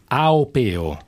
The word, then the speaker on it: Aupeo: come si chiama? – Aupeo. – Aupeo. – Au. – Aupeo. Ecco, pronunciamo bene perché così si capisce.